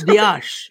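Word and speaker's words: Díaz: Luis Diaz. That's how you say it. That's how you, the Diaz, it's not Diaz, it's Diaz.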